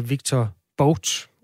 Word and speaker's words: Victor Bout: En basketstjerne med 0,7 gram cannabisolie, og så en våbenhandler, der hedder Victor Bout. Udtaler jeg det rigtigt? Det ved jeg ikke, om du kan hjælpe mig med, Trine. – Ja, det gør du, så vidt jeg ved.